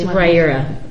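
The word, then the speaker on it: Riyria: It's Rhaera. At least that's how they say it on the... – Rhaera. – Rhaera. Apparently the last I is silent, so it's... – Rhaera?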